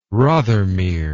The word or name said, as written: Rothermere